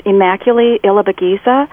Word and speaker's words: Immaculee Ilibagiza: Okay, number four is Immaculee Ilibagiza.